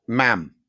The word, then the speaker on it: Ma'am: Ma'am, Ma'am, to rhyme with jam.